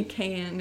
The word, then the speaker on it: Can: The director is Ken Evrenol. – Literally Can is his name. – Yeah, C-A-N.